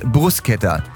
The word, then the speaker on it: Bruschetta: Bruschetta heißt Bruschetta.